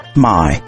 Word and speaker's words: 买: So pay attention to the pronunciation. Buy. 买。The third tone, 买。 – My. – Sell. My. – The fourth tone.